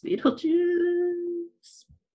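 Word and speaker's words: Betelgeuse: Apparently, it's pronounced Beetlejuice.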